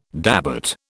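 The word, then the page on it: début: British English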